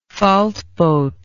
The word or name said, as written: faltboat